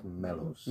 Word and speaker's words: Melos: It's pronounced Melos. – Melos. – That's right.